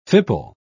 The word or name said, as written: fipple